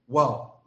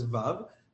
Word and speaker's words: vav: Manan Zechon Bracha was Iraqi, so he started off like other Iraqis saying "Wow." That's the way they pronounce it. No. Syrians. Iraqis, Timanim, and Tunisians say "Wow." Most of the rest of Am Yisrael says "Vav."